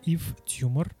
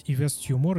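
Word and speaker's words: Yves Tumor: А, далее идет э, исполнитель на седьмой позиции, это Ив Тюмор. А, люди, незнакомые, например, с французским языком, могут прочитать Ивес Тюмор, и вы будете правы, потому что это английское произношение его имени.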